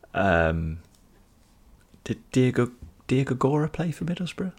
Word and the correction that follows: Diagouraga: Could oh, Di- Diagouraga. Diagouraga. What did I say? Diagora, I think you said.